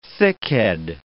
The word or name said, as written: thickhead